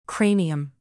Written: cranium

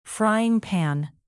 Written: frying pan

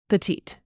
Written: petite

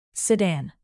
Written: sedan

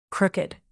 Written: crooked

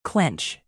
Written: clench

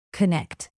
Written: connect

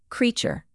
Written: creature

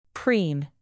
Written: preen

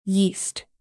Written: yeast

